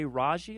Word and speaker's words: Raji: B.J. Raji, I believe is how you pronounce his last name.